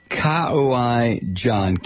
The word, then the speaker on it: Kauai: This hour is sponsored by Kauai John. Kauai John, okay? I'm sorry. You know, when you do the sponsorships, send me a pronouncer with some of these. Kauai John, K-A-U-A-I, Kauai John.